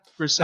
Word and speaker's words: Grousset: How would you pronounce that? Grousset. Pascal, Pascal Grousset.